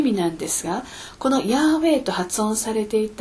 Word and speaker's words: ヤーウェイ: ところが今は多くの学者たちがヤーウェイと発音されていたに違いないと考えています。